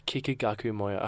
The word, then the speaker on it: Kikagaku Moyo: Kikagaku Moyo.